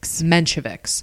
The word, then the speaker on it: Mensheviks: So, one would say that the Mensheviks, did I just say it wrong? – The Mensheviks.